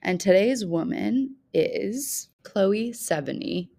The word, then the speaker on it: Sevigny: I have always in my head said Chloe Sevigny, which can't be right. So Sevigny is what we're going to go with.